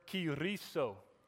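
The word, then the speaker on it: kyriso (κηρύσσω): That word proclaim is the word kyriso. Kyriso. You got to roll your R.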